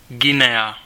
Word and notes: Guinea: /ˈɡɪniː/; American English

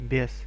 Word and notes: diable: IPA: /djabl/; French

